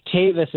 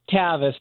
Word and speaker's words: Tavis: So it's actually it's a long A. Tavis is what my mom named me, but in law school I was Tavis too, so I go by either.